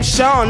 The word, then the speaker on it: Sean: Well, the first letter is pronounced with lenition, but since printed type, this is now represented by the letter H. Hence the name Sean.